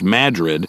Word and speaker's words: Madrid: spelled like Madrid, but pronounced Madrid,